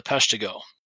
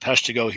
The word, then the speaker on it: Peshtigo: But I should post up regarding the Peshtigo here. I got to keep it in mind, so it's Peshtigo, not Peshtigo. People in Wisconsin are going to ream me if I say it wrong, because it's named after Peshtigo, Wisconsin.